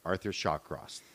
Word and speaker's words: Arthur Shawcross: So in today's story, we're going to take a closer look at Arthur Shawcross.